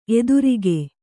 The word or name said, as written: ಎದುರಕ್ಕಿ